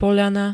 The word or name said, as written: POĽANA